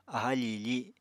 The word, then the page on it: aha fiifi: Apatani